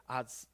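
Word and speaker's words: atsav (עצב): And I love the word that's used there, atsav, okay? A-T-S-A-V is how you pronounce it.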